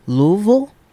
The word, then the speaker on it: Louisville: Well, last month I went to, and hopefully I pronounce this right because they get really mad when you don't. Louisville, yeah. – I believe is the correct pronunciation.